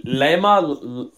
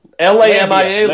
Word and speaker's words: Lamia: I forget how you pronounce it. – The yeah. la shit. – Lama. no. – L a m i a. Lambia. – Lamia. Lamia. – Lamia. Or Lambia. I don't know.